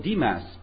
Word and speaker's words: Demas: But actually, we are going to study a person called Demas. I pronounce him that way. You probably pronounce differently. But Demas is a person who is mentioned in the Bible only 3 times.